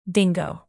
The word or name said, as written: dingo